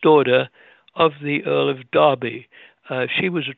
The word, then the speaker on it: Derby: Derby, to pronounce it in the English way. Here we would say Derby.